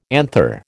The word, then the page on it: anther: American English